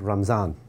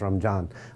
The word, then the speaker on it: Ramadan: Uh, in of, uh, Ramadan in Arabic, uh, the more Persianized pronunciation uh, that I was familiar with in South Asia, uh, we said Ramzan, or sometimes Ramjan.